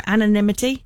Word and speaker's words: anonymity: Anonymity. – Yeah. – I don't even know if I'm saying it right. Anonymity. – That's right.